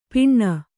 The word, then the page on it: ಪಿಣ್ಣ: kannada